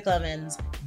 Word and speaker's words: Clemens: My name is Jessica Clemens, three syllables, and I'm here today with off-screen producer, Brandon.